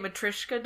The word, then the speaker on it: Matryoshka: The, how do you say it, Matryoshka doll? – Yes.